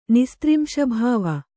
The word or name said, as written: ನಿಸ್ತ್ರಿಂಶಭಾವ